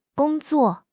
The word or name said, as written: gong1 zuo4